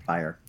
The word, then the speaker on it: Fryer: Am I saying that wrong? – Fryer. – With the late Sam Fryer. – Like fire with an R, as he was.